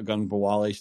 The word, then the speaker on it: Ogunbowale: You know, uh, Dare Ogunbowale still in the league. Royce Freeman still in the league. Good for you for pronouncing that correctly.